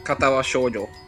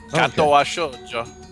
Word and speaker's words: Katawa Shoujo: Last week we played some Katawa Shujo. Did I say it right this time? – Uh-huh. – Did he get that? – Katawa Shoujo. – Katawa Shoujo.